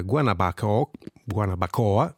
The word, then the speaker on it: Guanabacoa: Adesso Anna Carla Mas con Guanabacoa, ecco, la pronuncia giusta è questa, e poi parleremo di quanto sono importanti le parole.